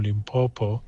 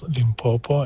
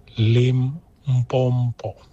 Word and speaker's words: Limpopo: I'm not even from Limpopo, but I get annoyed when people pronounce Limpopo and they go Limpompo.